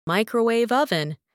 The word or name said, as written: microwave oven